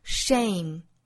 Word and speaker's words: shame: 那它的发音呢和 sham 很不同，这是一个双元音 a 后面再加鼻音，所以这个词发成 shame。虽然只差一个字母 e, 但是元音是不同的哈，一个是 sham, 伪善，一个是 shame, 羞愧。